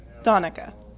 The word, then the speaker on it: Danica: Donica. – All right. Well, then why is that A, not an O? – Well, it turns out you can say an A more than one different way.